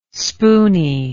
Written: spooney

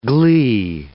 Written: glee

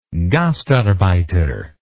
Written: gastarbeiter

